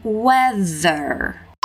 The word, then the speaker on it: weather: Tem um som de TH ali no meio, então vamos focar na pronúncia dessa palavra weather, weather, weather, weather.